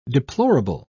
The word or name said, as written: deplorable